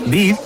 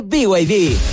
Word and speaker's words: BYD: ¿BID? ¿No es BID? – Nada que ver. Se escribe BID, pero se dice BYD.